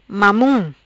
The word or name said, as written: Mamun